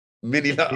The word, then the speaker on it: Minilite: Uh, one word. Yeah. Three syllables. – Yeah. – Minilite. Mini light.